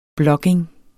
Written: blogging

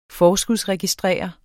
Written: forskudsregistrere

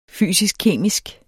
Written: fysisk-kemisk

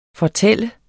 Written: fortæller

